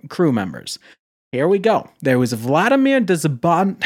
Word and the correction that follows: Dzhanibekov: For everyone, it's spelled DZH. – DZHANIBEKOV. Dizhanibekov. Vladimir Dizhanibekov. There we go.